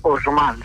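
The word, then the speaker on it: Osman: Οι τουρκικές λέξεις τονίζονται στο τέλος. Οσμάν, σ' άκουσα πάλι.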